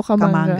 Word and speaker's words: Kamanga: Tama ba yung aking pronunciation? – Sa tingin ko po, Kamanga. – Kamanga, no?